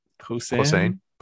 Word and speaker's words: Posehn: This is a bit of a complicated book, because I thought you're going to do a Thanksgiving episode, Brian Posehn. Okay, now I'm thinking. – I'm pretty sure that's how you pronounce it. – Now I'm thinking it rhymes with Saddam Hussein. That's how I'm going to remember it.